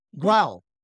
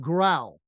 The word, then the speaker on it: Grau: And it's Brett Grau. Did I say that right? – No, it's Grau. – Grau without the L. Brett that, Grau.